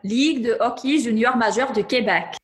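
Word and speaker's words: Ligue de hockey junior majeur du Québec: Ligue de hockey junior majeur de Québec.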